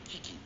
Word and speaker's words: QUI: QUI と書いてキキっていうバンドの伊原田っていうやつとのやり取りです。